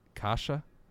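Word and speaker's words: Kaše: and they also acquired Andre Case Kasha. Yeah, that's how you say it.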